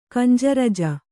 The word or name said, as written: ಕಂಜರಜ